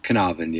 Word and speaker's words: Caernarfon: Caernarfon Castle is, you put some effort into the Welsh pronunciation, but Caernarfon, yeah.